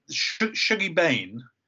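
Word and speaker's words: Shuggie Bain: It's interesting because Shuggy Bane, that's right, that's how you say it, isn't it? – That's right, yeah.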